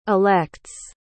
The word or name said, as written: elects